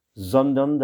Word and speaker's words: Sondern: Sondern das ewige Leben haben. Sondern, le S, c'est un Z, c'est un son Z, comme dans zigzag.